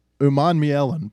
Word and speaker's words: Umanmielen: Also, Princely Umanu, how you say this? Umanmele. Do we know how to say this? I had it pulled up a minute ago. Here we go. Umanmielen, Princely Umanmielen, who recovered that fumble in the end zone.